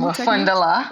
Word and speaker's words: Mafundala: As a master teacher of Mafundalini, did I pronounce that right? Mafundalini? – Mafundala. Technique? Mafundala. Y'all, I asked her before That's and I still right. got it wrong. It's Mafundala right.